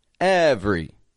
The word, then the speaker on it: every: Every. Every. Again, it's spelled every, E V E R Y, but I'm taking that middle E right out. Every.